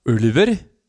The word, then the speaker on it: Ulver: by Ulver, which is the real pronunciation of the moniker.